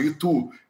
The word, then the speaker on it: ITU: O pessoal está me dizendo que é a mesma pronúncia que a cidade, Itu.